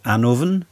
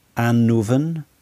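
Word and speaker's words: Annwn: The other world is often referred to as Anuvan. Anuvan. I've tried to render it in a simple English spelling there, Anuvan.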